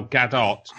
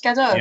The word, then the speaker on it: Gadot: Her name is Gal Gadot. I read that today. – Is that how you pronounce it? I thought it's Gadot. – Yes.